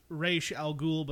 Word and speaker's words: Ra's al Ghul: Like, it's supposed to be pronounced Ra's al Ghul, but, you know, Dark Knight Trilogy Roz. Did it Roz.